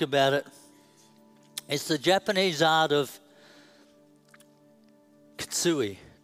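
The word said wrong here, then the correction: kintsugi: Did I say that right? Kitsui? What is it, darling? Kintsui. Kintsui.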